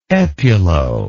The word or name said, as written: epulo